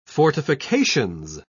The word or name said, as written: fortifications